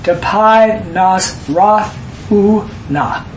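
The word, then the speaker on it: tapai nas roth u na (ταπεινοφροσύνη): Yeah, it's pronounced tapai nas roth u na.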